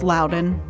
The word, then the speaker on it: Loudoun: It's also one of those counties people say wrong all the time. Loudoun? It's Loudoun.